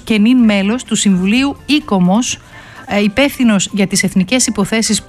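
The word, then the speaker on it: ICOMOS: Αν δεν το γνωρίζετε, αυτό ακριβώς κάνει το ΙΚΟΜΟΣ ή το, δεν ξέρω αν πρέπει να το πω, ΑΙΚΟΜΟΣ, κύριε Τανούλα. Πρώτα απ' όλα, καλησπέρα σας. – Καλησπέρα σας. Ναι, ΙΚΟΜΟΣ θα το λέγαμε, γιατί έτσι προφέρουν όλο με τον γαλλικό τρόπο.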